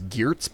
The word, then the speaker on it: Geertz: He's very much in the anthropological tradition of like Clifford Geertz, which I think people might know as Geertz, but I'm told the Dutch pronunciation is Geertz,